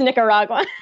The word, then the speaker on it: Nicaragua: Nicaragua, as he says, but it's Nicaragua. – That is correct, in the correct pronunciation.